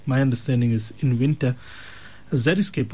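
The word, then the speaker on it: xeriscape: The word is zeriscape. The word is zeriscape. The spelling is actually unique because it starts off with an X. It's spelled with an X. X-E-R-I, and then the word scape, S-C-A-P-E.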